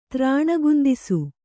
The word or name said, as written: ತ್ರಾಣಗುಂದಿಸು